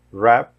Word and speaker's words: wrap: Trigésima, trigésima regra, todo WR tem som de R, ou seja, o W é mudo. Rap, wrestle, wrinkle, wrong, right, wrist. Mais uma vez. Wrap, wrestle, wrinkle, wrong, right, wrist.